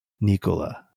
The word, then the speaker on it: Nikola: Nikola.